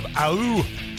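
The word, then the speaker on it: août: Welcome to Monday, the 29th of Aou. That's how they say it in France.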